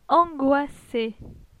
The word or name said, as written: Angoisse